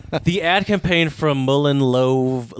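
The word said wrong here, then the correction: Lowe: How do you say Lova without an American? L-O-W-E. Mullen Low, probably. – Is it Low? Yeah, yeah, probably. – Low.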